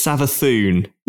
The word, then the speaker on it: Savathun: And they have revealed the Witch Queen Savathun, which I don't, there is an accent on the U, maybe it's Savathun. I don't know. – I think it's Savathun.